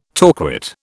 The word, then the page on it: torquate: British English